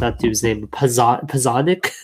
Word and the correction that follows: Porzingis: Um, Porzingis, right? – Porzingis. Porzingis. There we go.